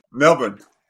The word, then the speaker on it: Melbourne: So even though you may now live in Sydney, you may now live in Melbourne, I can't, how do you spell, how do you pronounce Melbourne? – No, you did pretty well, Melbourne. – Melbourne.